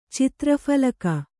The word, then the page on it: ಚಿತ್ರಫಲಕ: Kannada